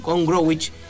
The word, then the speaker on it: Gombrowicz: Así que bueno, fue una charla en la cual él se animó a profundizar su trabajo de esta obra de, lo dije mal antes, es Witold Gombrowicz.